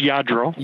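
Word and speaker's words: Lladró: And I was like, what the hell is a Ladro figurine? – Exactly. – right. Is that like a Hummel or like, I just don't know. Yeah. – Well, it's pronounced Yadro. – Yadro. Okay. See.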